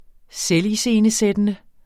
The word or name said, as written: selviscenesættende